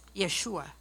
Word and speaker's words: Yeshua: His name is pronounced Yeshua, meaning Lord of Salvation, or the Lord of Salvation.